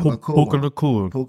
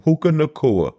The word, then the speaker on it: Puka Nacua: Akua? – Puka Nacua. – Puka Nacua.